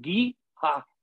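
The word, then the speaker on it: GEHA: Ya también Arrowhead tiene patrocinador y, aparte se pronuncia Giha.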